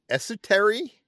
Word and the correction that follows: estuary: An estuary.